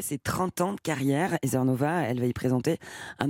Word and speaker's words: Heather Nova: Heather Nova. Vous vouliez me tendre un piège? – Pas du tout, c'est que je ne sais pas comment ça se prononçait. – Heather Nova.